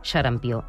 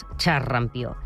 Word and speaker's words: xarampió: Ara que s'ha recuperat la transmissió endèmica del virus del xarampió, aprofitem per dir que es pronuncia xarampió i no pas xarrampió.